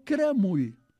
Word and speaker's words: caramull: Doncs a Riudoms, caramull es pronuncia cremull.